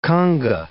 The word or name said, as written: conga